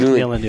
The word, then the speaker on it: Nuland: And Victoria Nuland, is that how you say your name? – Nuland, I think. – Nuland, yeah.